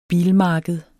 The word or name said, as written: bilmarked